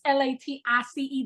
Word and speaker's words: Latisse: It's Latisse. There's no R. It's invisible and silent. It doesn't exist. Latrice is cool, but she ain't me. I'm Latisse.